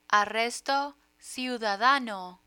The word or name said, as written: arresto ciudadano